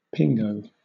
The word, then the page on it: pingo: British English